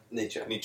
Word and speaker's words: Nietzsche: Nietzsche.